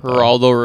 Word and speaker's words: Geraldo: And is it Geraldo or Geraldo? – Geraldo Rivera. – Okay. Soft G.